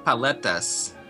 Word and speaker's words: paletas: And they have paletas. Is that how you say it? Paletas? – Yeah, those are those popsicles.